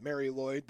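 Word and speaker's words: Mari Lwyd: It is not spelled Mary Lloyd, but that is how it's pronounced. The Mary Lloyd is actually spelled MARILWYD.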